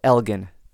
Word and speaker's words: Elgin: And he says it's a hard G. So, Elgin. Thank you, Neil.